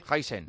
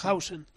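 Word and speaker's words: Huijsen: Heisen. – No, sí, se pronuncia Hausen. – ¿Se llama Hausen o Heisen? – Hausen? – Se pronuncia Hausen, sí, sí.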